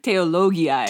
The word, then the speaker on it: Theologiae: Theologiae.